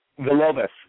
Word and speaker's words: Villalobos: Villalobos. And Villalobos, there we go. I was putting an extra A in there, you know, because I saw the, you know, you pronounce it the way, Jason, I am so sorry.